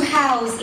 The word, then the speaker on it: house: The verb to house in its several forms should always be pronounced house.